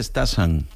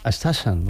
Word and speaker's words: Stassin: Me han dicho en Bélgica que se pronuncia Stassin. – Stassin.